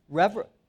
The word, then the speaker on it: reveille: Now, one of the first, what comes to your mind is, if you're in the military, you might think of revelry. Did I pronounce that right? Revelry. How do you say it? Reveille. Okay, reveille. I'm sorry. I think it's spelled differently than it's pronounced.